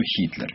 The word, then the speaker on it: Гитлер: Он сразу был Гитлер. А в эмиграции, для тех русских, которые были в эмиграции, так как он появился через немецкие, югославские, французские, чешские газеты... Он всегда был в произношении, как новое имя это был, всегда был Хитлер.